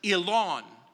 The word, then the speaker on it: Eglon: The battle against, and in the Hebrew it's pronounced Elon. In your Bible it might be translated Eglon, but the G is in the Hebrew silent.